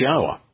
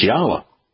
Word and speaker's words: Yahweh: It's Yahweh. But all you have to do is examine every Hebrew word with a yod, with a hey, and with a waw. And look how those letters are pronounced. It's Yahweh.